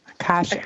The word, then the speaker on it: Akashic: So there, and even access the Akashi records. Oh my God, I said it right. Did I say that right? – Akashic. – Akashic.